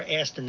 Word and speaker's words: ASTOR: Is it AST or ASTOR, the name of the group? – ASTOR, A-S-T-O-R.